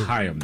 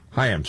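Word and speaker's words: Chaim: It's pronounced Haim, though, I think. – Haim, sorry.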